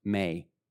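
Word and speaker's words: may (μή): The word not in the original Greek, two letters, M-E. It's pronounced may.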